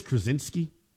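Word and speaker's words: Krawczynski: I think it's Krasinski, I think is the proper pronunciation.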